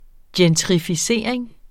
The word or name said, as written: gentrificering